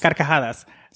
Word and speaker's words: Carcajadas: And the show is called Carcajadas. Is that my saying that right? – Carcajadas? – Carcajadas. So, oh, God, I need to practice.